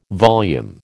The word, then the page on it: volume: American English